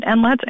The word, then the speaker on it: NLETS: Well, so there's a national data sharing system, which is called NLETS, or it's an acronym, but people pronounce it NLETS, N-L-E-T-S.